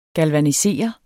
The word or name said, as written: galvanisere